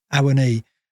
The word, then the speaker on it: Awoniyi: We're going to have to give up possession and counter, and we're going to have players in a Womney, a Womney. Oh, sorry, I can't pronounce his name. I've got to learn to say Awoniyi and Johnson, who have got pace and they can hurt defences.